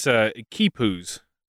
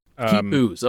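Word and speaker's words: quipus: Yeah, it is Kipus. – Kipus, okay, there you go. – It's spelled weird. It's Kipus.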